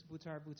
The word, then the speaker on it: Butar: Hi, my name is Raymundus Butar, R-A-Y-M-U-N-D-U-S, last name B-U-T-A-R, B-U-T-A-R.